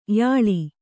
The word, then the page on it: ಯಾಳ: kannada